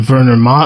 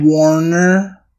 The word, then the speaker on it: Werner: His claim was backed by a German historian, Werner Maser? – Warner. – Nah, it's a V sound.